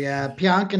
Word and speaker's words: Pionk: I think it's Pionk. Is that how you say it? – Yeah, yes, correct. And if I'm wrong, hopefully no one calls me out on it. – Yeah, exactly. Pionk in a first round pick went from the Jets, who are in cap hell.